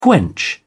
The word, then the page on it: quench: British English